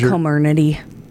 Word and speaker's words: Comirnaty: Comirnaty is how they've pronounced it.